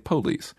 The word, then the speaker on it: police: And I just say that the word police has two syllables in the South where I come from, but a lot of people would say it police. It's one of those words that takes a stress on the first syllable down in the South, like guitar and insurance and police and Coca-Cola.